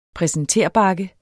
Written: præsenterbakke

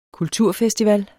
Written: kulturfestival

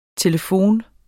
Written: telefon

